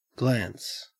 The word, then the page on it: glance: Australian English